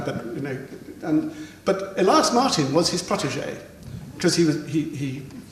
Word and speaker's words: Elias Martin: No, you pronounce it Elias Martin, but the Swedish artist.